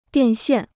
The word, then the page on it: 电线: diàn xiàn